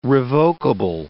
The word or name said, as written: revokable